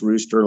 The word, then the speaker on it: Ruster: It is Rooster Sports, spelled R-U-S-T-E-R, but pronounced Rooster like the barn fowl.